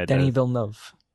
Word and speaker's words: Denis Villeneuve: Yeah, Denis Villeneuve.